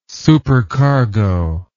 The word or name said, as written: supercargo